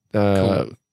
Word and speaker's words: Kiowa: The Kiowa Native Americans that... Kahiah is the pronouncer.